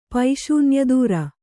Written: ಪೈಶೂನ್ಯದೂರ

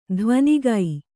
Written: ಧ್ವನಿಗೈ

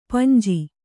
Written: ಪಾಂಜ

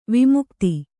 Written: ವಿಮುಗ್ಧ

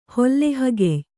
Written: ಹೊಲ್ಲೆಹಗೆಯ್

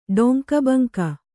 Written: ಡೊಂಕಬಂಕ

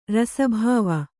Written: ರಸಭಾವ

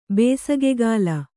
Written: ಬೇಸಿಗೆಕಾಲ

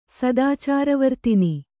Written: ಸದಾಚಾರವರ್ತಿನಿ

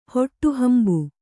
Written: ಹೊಟ್ಟುಹಂಬು

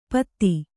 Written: ಪೊತ್ತ

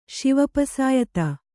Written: ಶಿವಪಸಾಯತ

